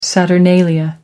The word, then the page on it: saturnalia: American English